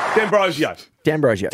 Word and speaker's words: D'Ambrosio: There's a player for Essendon, and this is the correct way to say this player's name. – Goal! Beautiful! For D'Ambrosio.